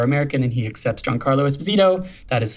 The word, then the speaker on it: Esposito: Technically, we should all be saying Giancarlo Esposito. It's my Italian best attempt at my proper Italian pronunciation, as Giancarlo is truly Italian. Most people kind of Americanize his last name and say Esposito. But technically, it is Giancarlo Esposito.